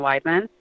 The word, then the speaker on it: Weidman: They're all at Katrina Weidman. And my last name is spelled W-E-I-D, man, like if you're getting carded somewhere.